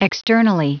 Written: externally

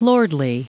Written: lordly

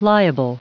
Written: liable

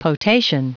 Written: potation

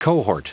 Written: cohort

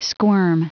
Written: squirm

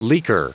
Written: leaker

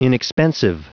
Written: inexpensive

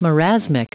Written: marasmic

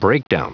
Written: breakdown

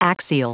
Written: axial

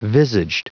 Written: visaged

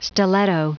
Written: stiletto

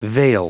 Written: veil

